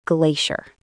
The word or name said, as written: glacier